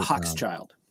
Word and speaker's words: Hochschild: The guy that wrote Hochschild.